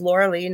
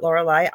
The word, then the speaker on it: Lorilei: It's Lorilei, not Lorelei.